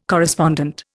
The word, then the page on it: correspondent: Indian